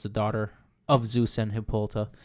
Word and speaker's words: Hippolyta: So one of the, I don't know if you guys know this, but one of the myths was that Hippolyta. Is that how you say her name? – Yeah, her mother. I say Hippolyta, but yeah.